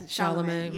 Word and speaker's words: Chalamet: And that's how you say his name? – Yes, Chalamet. Yeah, mm-hmm.